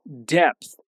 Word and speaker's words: depth: Outra palavra interessante aqui que aparece bastante no cotidiano também é depth, que se escreve D-E-P-T-H e quer dizer profundidade. Depth. Depth. Sim, a pronúncia é meio chatinha de início, mas se torna bem tranquila com a prática.